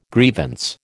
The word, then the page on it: grievance: American English